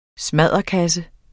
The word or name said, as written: smadderkasse